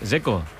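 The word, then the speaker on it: Džeko: Porque DZ, ¿cómo se pronuncia? – Dzeko. Bruno Dzeko. Dice que es Dzeko. – Como tirando a J, Dzeko o algo así.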